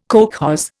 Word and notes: kolkhoz: Indian

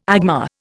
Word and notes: agma: Indian

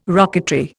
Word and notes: rocketry: Indian

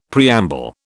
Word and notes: preamble: British English

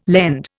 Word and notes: lend: Indian

